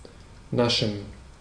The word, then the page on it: naschen: /ˈnaʃn̩/; /ˈnaʃən/